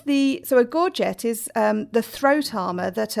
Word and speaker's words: gorget: So one thing you mentioned earlier was the gorgets. Now, I thought this was gorge, and because that sounds more romantic than gorget, but I listened to a pronunciation tutorial,